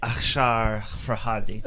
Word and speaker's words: Asghar Farhadi: His name is, I'm going to try to say it correctly here, Asghar Farhadi. – It's probably Akshar Farhadi. I'm half Persian, so.